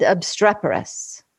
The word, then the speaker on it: obstreperous: This love of words, how did I do on pronunciation? – Oh, perfect. Perfect. Ephemeral, diaphanous, and squat, and obstreperous.